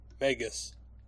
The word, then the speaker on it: Megas: So, to start, is it Mega or Megulus? – Megas. – Ah, Megas. Because for some reason I always thought it was Mega.